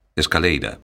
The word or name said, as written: escaleira